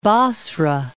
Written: Basra

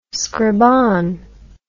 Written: scribanne

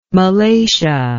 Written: malacia

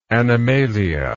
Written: animalia

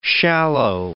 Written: shallow

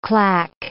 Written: claque

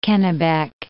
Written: Kennebec

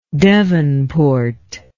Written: devonport

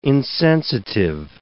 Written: insensitive

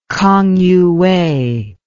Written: kangyuwei

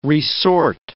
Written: re-sort

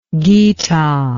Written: gita

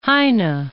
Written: Heine

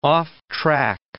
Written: offtrack

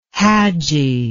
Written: hadji

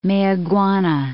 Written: Mayaguana

